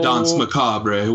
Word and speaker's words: Danse Macabre: So the Danse Macabre, which we still use the French pronunciation for.